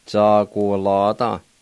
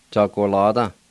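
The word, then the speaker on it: tsaquolada: Number two, bluebird, Za-gu-la-da. Za-gu-la-da. Za-gu-la-da.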